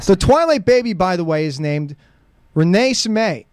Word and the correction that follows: Renesmee: Renesmee, is that what it is? – I'm looking at the pronunciation.